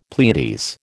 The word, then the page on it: Pleiades: American English